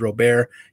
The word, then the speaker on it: Robert: That is how it is officially pronounced, is Robert. He has given people permission to call him Robert because people screw it up a lot.